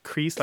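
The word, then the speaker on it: creece: Yeah, so it has nothing to do with looking like creases or no, it's just a dialect pronunciation of cress, right, as in watercress.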